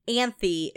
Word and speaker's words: Anthy: Anthy. It's like Anthony, but like missing a syllable.